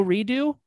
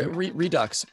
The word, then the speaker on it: Redux: There actually, speaking of things I'm super excited about, Orbital Redo. – Dude. Redux or Redux. – But, I like to say redo. – Well, because you're pronouncing it correctly.